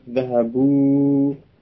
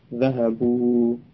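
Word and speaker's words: Zahabu (ذهبوا): Zahabu, yang ada hanya bunyi wawu, wawu sukun sebagai bacaan panjang dari dhammah sebelumnya. Zahabu, jadi alifnya tidak terucap.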